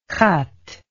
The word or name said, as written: xat